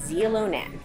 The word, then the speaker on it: Xilonen: It's that you spell it X-I-L-O-N-E-N. That's the name of the restaurant, pronounced Zilonen.